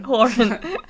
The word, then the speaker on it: Horan: I've read for all sorts of celebs, even Zayn Malik and Niall Horan. Is that how you say his name? – Horan. Actually.